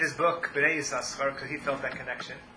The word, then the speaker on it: Yisachar: No, this one also, but we pronounce it with both sins. Tzvi Yisachar.